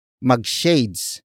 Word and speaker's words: magshades: For example, mag-shades ka para astig. Mag-shades ka para astig. Mag-shades ka para astig. It means, wear shades so you would look cool. Word for word it's, mag-shades ka para astig. Mag-shades ka para astig.